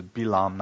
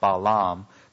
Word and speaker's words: Bilaam: Incidentally, the Hebrew pronunciation is Bilaam, not Balaam.